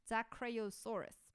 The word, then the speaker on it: Dicraeosaurus: So, yes, I had to actually frenetically type that out, so I did, so I didn't mispronounce it, and it is Dicraeosaurus.